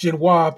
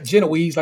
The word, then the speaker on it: Genoise: It's going to be a Genoise sponge, which Shabir pronounced Genoise because that's how you pronounce it, not Genoese, like the rest of them punkers be saying.